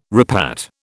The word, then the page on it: repat: British English